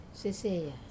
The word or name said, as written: seseya